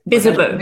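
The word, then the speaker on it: Bizzabo: Is that how you pronounce it? Bizabo? – Bizabo. Make it true.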